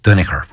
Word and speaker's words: Duinnachair: Kafusin, because my father used to pronounce it, it was spelled D-U-I-N-N-A-C-H-A-I-R, which is Duniker, which is Danaher.